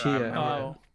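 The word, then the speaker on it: Tchia: It's T-C-H-I-A. – Huh. Oh, that's why I can't find it. – T-C-H-I-A. I'm assuming it's pronounced Chia. – Chia.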